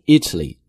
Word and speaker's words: Italy: 而意大利这个国家的名字是 Italy，重音呢是在第一个音节 E 上面， Italy。 So if it is country then it's Italy， but if it is the person or it is something related to the country it's Italian。